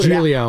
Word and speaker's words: Giglio: Joe Giglio, I forget how you pronounce his name. He already put Giglio.